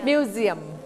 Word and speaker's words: museum: มี 5ตัว A E I O U อันนี้ S อยู่ระหว่างสระ 2 ตัวเสียงตัว S เวลาเราออกเสียงให้เปลี่ยนเสียงเป็นเสียงตัว Z เสียงตัว Z ทันทีดังนั้นเราไม่ออกเสียงว่ามิวเซียมเราเสียงว่ามิวเซียมมิวเซียม